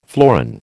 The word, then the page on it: florin: American English